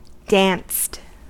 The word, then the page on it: danced: American English